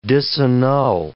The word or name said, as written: disannul